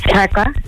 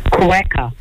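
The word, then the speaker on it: quokka: Cracker. – Sorry? – Quacker.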